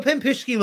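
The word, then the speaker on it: Pampushki (пампушки): Well, I paused because I was like, pampushki, right? Did I say it right? – Pampushki, yeah.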